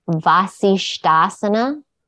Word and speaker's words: Vasisthasana: And it's the pose Vasisthasana.